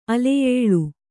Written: ಅಲೆಯೇಳು

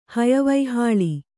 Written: ಹಯವೈಹಾಳಿ

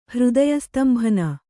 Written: ಹೃದಯಸ್ತಂಭನ

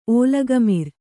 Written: ಓಲಗಮಿರ್

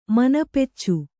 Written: ಮನಪೆಚ್ಚು

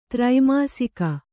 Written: ತ್ರೈಮಾಸಿಕ

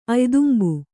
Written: ಅಯ್ದುಂಬು

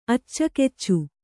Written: ಅಚ್ಚಕೆಚ್ಚು

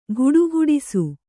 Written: ಘುಡುಘುಡಿಸು

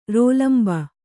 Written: ರೋಲಂಬ

